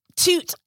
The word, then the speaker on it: Toot: And we have our next LA startup spotlight for you, an amazing tutoring company, on-demand tutoring, Toot app. Right? Am I saying it right? – Yeah.